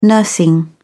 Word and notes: nursing: British English

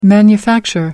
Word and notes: manufacture: American English

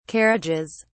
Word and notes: carriages: British English

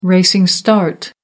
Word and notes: racing start: American English

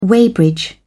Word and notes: weighbridge: British English

